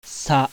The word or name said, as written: SA